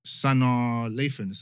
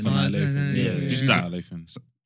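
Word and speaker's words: Sanaa Lathan: Sana Lathan. That's how you say it. – Sana Lathan.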